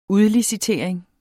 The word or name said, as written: udlicitering